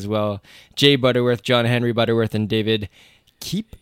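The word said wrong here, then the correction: Koepp: I think is how you pronounce it. That's what I'm seeing now. Kep, I think they say that name.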